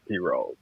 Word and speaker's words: pirogue: A lot of people call them a Piro or something like that, but it's pronounced Piro.